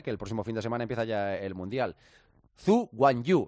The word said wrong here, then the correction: Zhou Guanyu: ¿se dice así, Carmichael? Zhu Guanyu. – Es Zhou. Es como se dice. – Zhu Guanyu Zhou. – Es Zhou Guanyu. Es Zhou Guanyu. Es así.